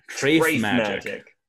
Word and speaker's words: Treif Magic: Treif Magic.